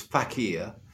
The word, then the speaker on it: fakir: I'm going to hand over to you to comment on that, Brian, but I'll just say I think the word he's using there is often pronounced as fakir, F-A-K-I-R.